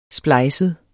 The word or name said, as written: splejset